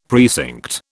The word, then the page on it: precinct: British English